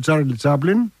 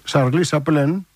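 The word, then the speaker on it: Charlie Chaplin: Είναι από τους μοντέρνους καιρούς του Τσάρλι Τσάπλιν, 1936, Σαρλί Σαπλέν, που λένε και οι Γάλλοι, με αυτή την άθλια προφορά.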